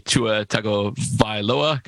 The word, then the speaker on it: Tua Tagovailoa: That was Joe Burrow of Cincinnati, Tua Tagovailoa. Got to be careful when that was right. First time I've ever said it right.